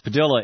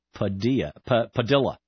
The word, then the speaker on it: Padilla: District Judge Henry Floyd in Spartanburg, South Carolina, and one of the folks in our chat room, I believe, mentioned, yes, Padilla is the Spanish pronunciation, but he pronounces it Padilla, which is, you know, go figure.